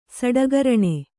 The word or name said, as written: ಸಡಗರಣೆ